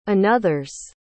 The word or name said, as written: another's